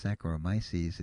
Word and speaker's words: Saccharomyces: And you got the first name, which is pronounced Saccharomyces, and here comes the second name, which is thankfully not as long. B-O-U-L.